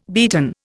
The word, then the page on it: beaten: Indian